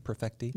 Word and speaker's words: Perfecti: Did I say that correctly? Perfecti? – Yeah, that's right.